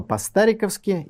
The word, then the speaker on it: По-стариковски: В эфире программа «По-стариковски», именно так, с ударением на букву «А».